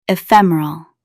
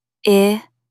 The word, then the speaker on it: ephemeral: Stress the second syllable, ephemeral.